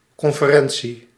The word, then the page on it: conférence: France; [kɔ̃.fe.ʁɑ̃s]